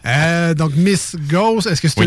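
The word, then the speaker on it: Gose: gauze ou une gaza, si on veut bien le prononcer à l'allemande. – Gaza. – Gaza. Ah avec un accent sur le O.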